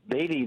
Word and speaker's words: Beatty: Yeah, Beatty.